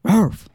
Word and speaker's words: Rowlf: And it's not Ralph. I always called him Ralph, but it's spelled R-O-W-L-F. – Ralph. – It's a lot. – Ralph. – So Ralph, I think it's supposed to be like Ralph.